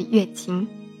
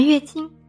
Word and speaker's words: 乐清: 今天我们先来讲讲乐清的白石遗址。和大家说乐清白石遗址之前呢，先和大家简单的介绍下乐清。乐清正确的读法是乐清，音乐的乐。为什么要强调乐清的读音呢？因为有百分之九十的外地朋友会把乐清读成乐清。